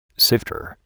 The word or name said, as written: sifter